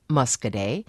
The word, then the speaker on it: Muscoda: You know, Jackie John from Richland Center called in and asked Justin Williams to pronounce Musk-a-day, and Justin, yay, got it right. And that's a hard one. Muscoda. It looks like Muscoda.